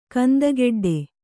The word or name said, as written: ಕಂದಗಡ್ಡೆ